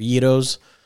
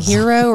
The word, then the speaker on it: gyro: I've been saying hero or gyro. It does start with a G.